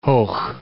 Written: Hoogh

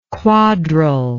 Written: quadrel

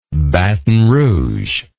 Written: baton rouge

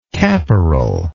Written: caporal

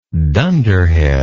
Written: dunderheadedness

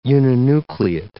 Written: uninucleate

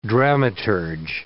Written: dramaturg

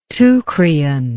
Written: teucrian

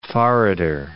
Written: forrarder

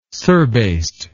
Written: surbased

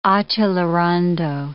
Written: accelerando

